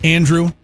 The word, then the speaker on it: Andruw: Is this guy's name pronounced Andrew, the Monasterio guy? Because it's spelled A-N-D-R-U-W.